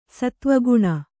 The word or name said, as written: ಸತ್ತ್ವಗುಣ